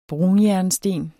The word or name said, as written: brunjernsten